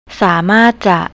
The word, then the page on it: samartja: thai